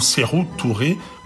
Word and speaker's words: Sékou Touré: Ce n'est d'ailleurs pas le seul morceau où la chanteuse encense Sékou Touré ou Sérou Touré, comme elle le prononce avec un accent guinéen impeccable.